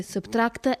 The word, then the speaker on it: SBTRKT: Si legge Subtract e si scrive SBTRKT, soltanto le consonanti, proprio a sottrarre.